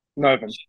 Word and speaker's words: Novum: The name is that Novum? Yeah. Novum.